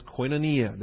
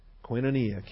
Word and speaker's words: koinonia: The term is koinonia. The term is sharing. It's our term for fellowship. Koinonia. K-O-I-N-O-N-I-A. It's the long O. It's the omega. The one that looks like a W. Koinonia. K-O-I-N-O-N-I-A.